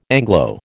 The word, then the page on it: Anglo: American English